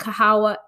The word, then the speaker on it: Kahawa: It is K-A-H-A-W-A.